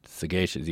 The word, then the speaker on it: sagacious: See, that throws you off because it's the word is sage, but then sagacious, you hit the hard G with sagacious.